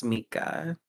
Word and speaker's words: Mika: And a lot of people call me Mika, but the proper pronunciation is Mika.